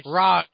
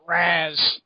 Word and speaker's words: Ra's: When you read this, it's pronounced Ra's, not Raz. The movie got it wrong.